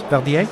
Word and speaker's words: Verdier: Henri Verdier. Thank you. Did I get the name right? Verdier? – Yes.